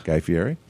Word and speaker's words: Fieri: Guy Fieri.